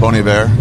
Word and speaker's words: Bon Iver: Bon Iver.